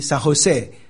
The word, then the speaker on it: San Jose: 其实你要读西班牙语，你就知道那个 J San Jose，那 J 是怎么念的？是念 H 的音嘛？